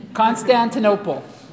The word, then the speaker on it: Constantinople: Constantinople is how it's pronounced, if I said it differently before.